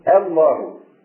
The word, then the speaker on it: الله: يعني مثلا لفظ الجلاله يبدا بالالف، وطبعا الالف اذا كانت اول الكلام تكون كالهمزه، يعني تنطق همزه، نعم تنقطع يعني، فتقول الله، من الخطا ان تقول الله الله، لا حظ للهمزه في التفخيم، اي همزه لا تفخم، لا حظ للهمزه في التفخيم، فتقول لتقول الله